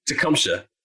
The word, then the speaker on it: Tecumseh: I did see, and bro, please correct me if I'm wrong, but your actual first name is Tecumseh. Am I saying that wrong? How am I saying it? – No, Tecumseh. – Tecumseh. Okay.